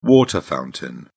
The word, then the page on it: water fountain: British English